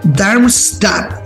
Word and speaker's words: Darmstadt: midiendo frente al Darmstadt.